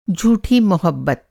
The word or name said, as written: झूठा